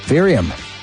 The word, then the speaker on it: Varium: Variorium. Do you know that one? – How do you spell that word? – V-A-R-I-U-M. – Varium.